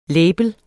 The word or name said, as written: label